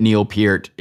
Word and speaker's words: Neil Peart: We know that Neil Peart is how you pronounce Peart.